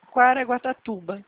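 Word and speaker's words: Caraguatatuba: Quaraguatatuba.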